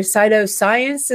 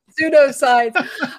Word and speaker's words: pseudoscience: Cytoscience, is how do you say that? – Pseudoscience.